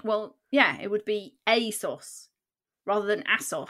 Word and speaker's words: ASOS: ASOS. How do you say that? – ASOS. – Because it's ASOS, yeah. – As seen on screen. – Holy shit.